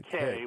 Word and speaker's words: Cay: Which is a little play on my name, David Kay, which is spelled C-A-Y and District of Columbia.